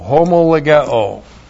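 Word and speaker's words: homologeo: H-O-S. Hosts is, should be translated who. And then the verb homo legao. H-O-M-O-L-O-G-E, long O, homo legao.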